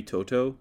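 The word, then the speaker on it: To'o To'o: I'm gonna go to the Tennessee Georgia game here and go with linebacker Henry Toto. Is that how you say it? – I think you're right.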